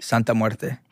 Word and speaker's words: Santa Muerte: Santa Muerte? – Santa Muerte.